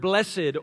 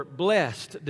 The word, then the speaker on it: blessed: Each of these Beatitudes begins with that little word, blessed or blessed, depending on how you choose to pronounce it.